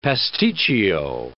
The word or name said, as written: pasticcio